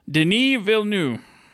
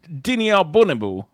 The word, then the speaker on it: Denis Villeneuve: Denis Villeneuve. – Okay. Yeah. Denis Albonibu.